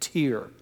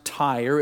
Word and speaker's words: Tyre: Because of the similarity to our last name, I was hoping I could find some justification for pronouncing it Tyre. But it really is pronounced Tyre. It comes from the Hebrew word tsor, T-S-O-R, we would spell it.